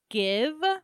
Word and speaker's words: GXVE: It's called Give, but it's spelled like G X V E.